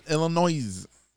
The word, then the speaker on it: Illinois: It's got an S at it. – Illinois. – Illinois. But that S is silent, bitch.